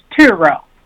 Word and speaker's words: Tureaud: And it's Tureaud.